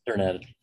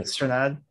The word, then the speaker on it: Strnad: How do you pronounce his last name? Do you know? – Strnad. – Is it Strnad? Yeah.